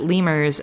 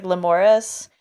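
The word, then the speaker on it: Lemures: But like in my head, I thought they were going to be monkeys. – Yeah, I don't know if those are named after this thing. In the sub, it's pronounced like Lemuris.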